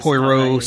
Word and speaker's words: Poirot: P-O-I-R... – Poirot something.